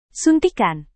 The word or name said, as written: suntikan